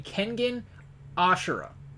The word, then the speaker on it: Kengan Ashura: Can you just say it correctly? Kengan Ashura. Or Ashura. No, you have Kegan. Me saying it wrong now. Kengan Ashura.